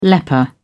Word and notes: leper: British English

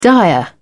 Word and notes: dire: British English

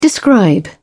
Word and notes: describe: British English

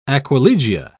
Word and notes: aquilegia: British English

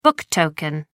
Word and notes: book token: American English

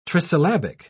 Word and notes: trisyllabic: British English

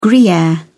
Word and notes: gruyere: British English

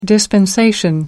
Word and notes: dispensation: American English

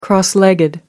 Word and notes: cross legged: American English

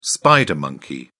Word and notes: spider monkey: British English

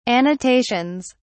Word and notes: annotations: British English

